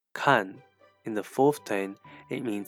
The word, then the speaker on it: 看: Kan, which means to look, watch, or read, and is normally pronounced and heard in the fourth tone. However, it can also be pronounced as kan, which means to look after.